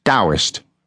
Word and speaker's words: Taoist: It is actually written Taoist, T in Tommy, A O I S T. So it's written Taoist and pronounced with a D, Taoist.